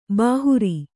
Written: ಬೇಹರ

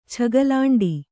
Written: ಛಗಲಾಂಡಿ

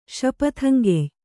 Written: ಶಪಥಂಗೆಯ್

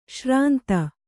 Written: ಶ್ರಾಂತ

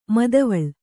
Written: ಮಾದವಾಳ

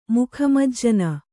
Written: ಮುಖಮಜ್ಜನ

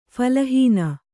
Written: ಫಲಹೀನÀ

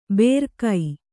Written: ಬೇರ್ಕೈ

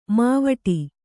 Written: ಮೈವಡೆ

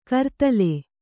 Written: ಕರತಾಲ

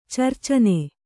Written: ಚರ್ಚನೆ

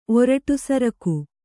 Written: ಒರಟುಸರಕು

